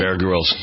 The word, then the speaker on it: Grylls: Yeah, Bear Grylls.